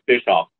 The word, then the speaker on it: Bischoff: Russell, you were the best first pronouncer of my last name ever. It's Bischoff. So you were very close.